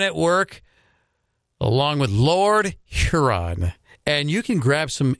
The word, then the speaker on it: Huron: Please pronounce the H on socials. – When you don't. Sounds like you're saying Lord Urine.